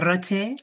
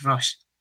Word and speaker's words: Roche: ¿Mejor Roche o Roche? – Pues en España sin duda es Roche, fuera de España es Roche.